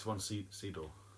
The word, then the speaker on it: Sydow: So, it's spelt S-Y-D-O-W. – But how's it pronounced? – Sydow. I will find out. – How do you pronounce Max von Sydow? Sydow. As how I think it's pronounced. – How to pronounce. Ah, there's a YouTube video. There you go. – Right. Are you going to play it? – Von Sydow. – Sydow. – Oh, right. Oh, well, that was wrong.